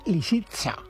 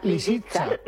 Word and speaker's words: Lisitsa: que está interpretada por Valentina Lisitsa. Lo he dicho bien. – No, lo has dicho, lo has dicho fatal. – A ver cómo es. – Pues hombre, Lisitsa. – ¿Lisitsa? – Lisitsa. Lisitsa, con S.